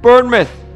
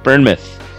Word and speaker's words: Bournemouth: Before you start, I just love how you pronounce it, like Plymouth. It sounds exactly like Plymouth, except with just random letters inserted instead of Plymouth. Bournemouth.